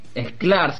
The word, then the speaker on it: Sklar: Juan Sklar se pronunciaría, pero es con S, S-K-L-A-R.